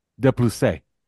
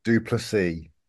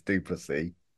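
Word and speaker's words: Du Plessis: And his last name is D-U space P-L-E-S-S-I-S. – How do you say it? – De Plusse. – No, Duplessis. – Is Duplessis? – Yes, he's South African. It's Duplessis. – Oh, okay. All right. There you go. So if he was French, is Duplessis?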